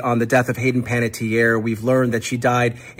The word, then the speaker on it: Panettiere: I could never remember how to pronounce her last name again. – Panettiere. – Yeah.